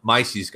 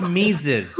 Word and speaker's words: Mises: But so LP Kentucky is now ran by Mises guys, though. So Mises, it's pronounced Mises.